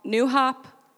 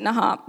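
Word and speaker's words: NuHop: And Josh works at a camp down in Mohican called New Hop. They pronounce it Nahop, but I'm not going to do that like 30 times up here because I think it sounds silly.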